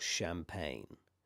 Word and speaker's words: champagne: Now there's one combination that you might find where it's G-N-E, G-N-E, like cologne or champagne. Now in these circumstances, the E is a magic E, so that's also silent.